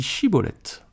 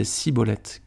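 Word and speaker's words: Schibboleth: Il lui disait alors Eh bien, dis Chibolette. Et il disait Cibolette, car il ne pouvait pas bien prononcer.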